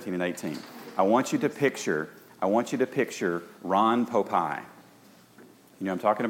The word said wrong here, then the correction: Popeil: I think I'm saying his name right. Popeil. Thank you. I knew somebody would help me. Awesome. Ron Popeil.